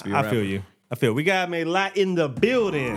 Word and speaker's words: Mélat: Are we pronouncing it right? – No. – Oh. – Mélat. – There we go.